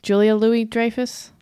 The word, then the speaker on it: Julia Louis-Dreyfus: Yeah, I mean, the fact that Julie Louis-Dreyfus, did I say that right? I always get her name wrong. Julia Louis-Dreyfus? – I think that's right.